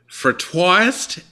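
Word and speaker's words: frottoir: So, welcome, and correct my pronunciation of the fratoist, and tell us what that actually is. – Well, thanks for having me. It's the pronunciation is fratois, which fratois. is French for rub board.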